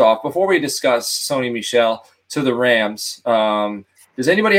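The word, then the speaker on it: Michel: I've always said Michelle. – Michelle. Okay. So we're all right. So it's Michelle.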